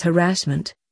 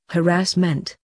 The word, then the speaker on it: harassment: Possibly today's favorite mispronunciation is harassment, pronounced as harassment, whereas the correct emphasis should be on the first and the third syllables, harassment.